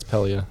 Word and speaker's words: Pelia: Pelia. Is that how you pronounce it? – Yeah, it's Pelia. Just think like Peloton.